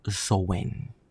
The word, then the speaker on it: Samhain: Para otros que estén un poquito más influenciados por el neopaganismo y la Wicca, estamos hablando de el Samhain y los que sean más apegados a el término correcto, estamos hablando del Zoen. Porque una cosa es cómo se escribe y otra cosa es cómo se pronuncia.